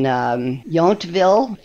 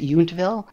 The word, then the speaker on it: Yountville: one in New Orleans and one in Yountville. That's how you pronounce it, Yountville.